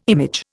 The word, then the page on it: image: Indian